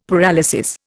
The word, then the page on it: paralysis: Indian